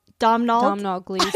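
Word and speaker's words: Domhnall Gleeson: Domhnall Gleason. – His name is Domhnall. – It's something like Donald Gleason. Domhnall. It's spelled Domnhall. – Okay, so maybe it is Domhnall. – It's an Irish thing.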